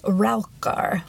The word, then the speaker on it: Raukar: So the main reason that people come to Fårö is to see the Raukar, which I know I am pronouncing wrong. Is that the stones thingies? Yes. – So okay. it's spelled R A U K, Rauk.